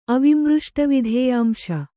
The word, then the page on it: ಅವಿಮೃಷ್ಟವಿಧೇಯಾಂಶ: Kannada